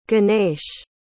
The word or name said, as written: ganesh